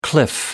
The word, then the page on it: cliff: American English